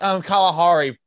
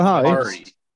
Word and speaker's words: Kalahari: Kalahia Resort and Conventions. – It's actually pronounced Kalahari Resorts and Conventions. – Kalahari. Thank you.